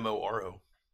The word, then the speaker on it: Moro: Am I saying that right? Moro? – I believe so. Yeah. – Okay. – It's the Moro.